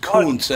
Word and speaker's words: Gutekunst: By the way, it's pronounced Kuntz anyway, so you might want to. Hey, Gutekunst.